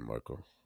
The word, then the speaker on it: Markle: Yeah, so apparently Meghan Markle, did I say it right? Markle? – Yeah, Meghan Markle.